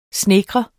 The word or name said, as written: snedkre